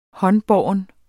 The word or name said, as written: håndbåren